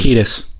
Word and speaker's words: Kiedis: Kiedis. – Anthony Kiedis.